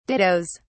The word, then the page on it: dittos: British English